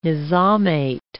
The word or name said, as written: nizamate